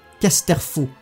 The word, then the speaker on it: Casterfo: Ransolm Caterfo. Casterfo, qui est un jeune politicien qui a des idéaux diamétralement opposés à ceux de Léa.